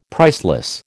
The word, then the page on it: priceless: American English